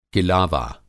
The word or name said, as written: किलावा